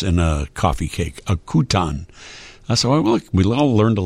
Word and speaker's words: Kuchen: Somebody answered the question of what kuchen is, K-U-C-H-E-N. It's a coffee cake. Kuchen, apparently, is how it's pronounced.